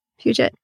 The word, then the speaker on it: Puget: She's a millennial, but she works for a public radio station, Puget Sound. Did I say that right? – Puget.